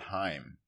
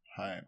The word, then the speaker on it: Haim: How do you spell that? – H A I M. Jewish word. – Haim. See, it'd be. Well, it's an H. That's how you spell it. It's their last name. – It means life in Hebrew.